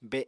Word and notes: be: Apatani

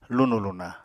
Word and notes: lunù-luna: Apatani

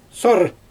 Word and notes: sorr: Xyzyl

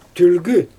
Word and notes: tylgy: Xyzyl

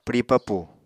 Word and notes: pwri papù: Apatani

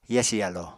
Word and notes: yasi yalo: apatani

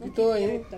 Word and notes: pitoi: Ansus

